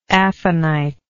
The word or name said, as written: aphanitic